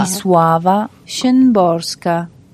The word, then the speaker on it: Wisława Szymborska: Wisława Szymborska. – Bien. Muy sabemos bien. Que ya se va a repetir conmigo, por favor. – Así otra vez, por favor. – Wisława Szymborska.